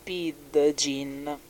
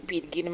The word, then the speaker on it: pidgin: E molti lo pronunciano pidgin, ma in realtà si dice pidgin.